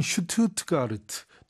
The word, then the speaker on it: Stuttgart: 지금 에스테론 모터스는 a renowned car manufacturer, 유명한 자동차 제조업체로서 headquartered in Stuttgart, 우리식으로 하면 슈투트가르트. 독일로 발음하면 이렇죠.